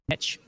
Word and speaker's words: niche: And it has to be this very niche or niche, depending how you say it, audience.